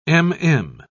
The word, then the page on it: m.m.: British English